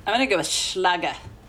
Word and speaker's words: Schlage: It's Schlage. – Hmm. – There is a video on their website saying, yeah, we've heard people pronounce our name a lot of different ways. It's Schlage.